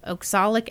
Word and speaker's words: oxalic: Honest to God, he said he'd seen Bill mix cyanide of potassium with oxalic acid. How do you say that? O-X-A-L-I-C? – O-X. What? One more time. – O-X-A-L-I-C. – Oxalic, yeah.